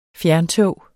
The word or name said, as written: fjerntog